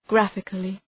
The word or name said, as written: graphically